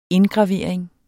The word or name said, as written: indgravering